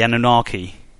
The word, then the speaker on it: Anunnaki: So are you aware of the Sumerians when they talk about the Anunnaki? I think I said that right, the Anunnaki. – Yes.